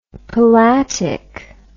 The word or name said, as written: palatic